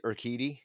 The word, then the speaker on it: Urquidy: But the Urquidy would have been, is that how you say it? Urquidy? – Yeah.